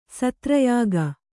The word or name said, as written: ಸತ್ರಯಾಗ